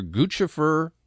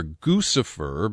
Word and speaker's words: Guccifer: The Romanian hacker, whose name is either Guccifer because he's a Gucci lover, or Guccifer because he fancies himself as devilish. The name is spelled G-U-C-C-I-F-E-R, and it's a nom de guerre.